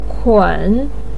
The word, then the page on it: kun: 3